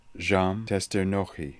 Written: Djamtesternokhi

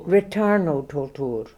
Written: retarnotoltoor

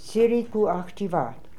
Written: tsirikooakhtivat

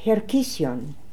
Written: Herkission

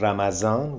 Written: Ramadan

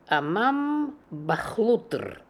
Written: Amambakhlootr

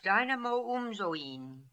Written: Dynamoumzoin

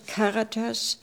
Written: Karatas

